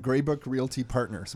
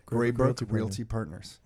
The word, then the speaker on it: Greybrook Realty Partners: So Dylan and Darcy are here from Greybrook Realty Partners. Am I saying it right? – That's it. – Greybrook Realty Partners. – That's the one.